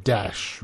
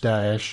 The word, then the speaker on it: Daesh: You know, I hate the regime using ISIL, so now they're using Daesh, but he pronounces it Dash, which is not quite right as far as I'm aware. If anybody wants to correct me, I've told you how you can contact me. I do believe it is actually pronounced Daesh. I've even seen it transliterated as Daesh, so a bit more emphasis there on the A, but Daesh I think is the best one.